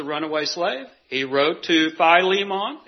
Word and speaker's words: Philemon: In verse 9, Onesimus, the reason for the letter, and I can never say, Philemon, is that how you say that? Philemon, okay, one of the syllables were wrong.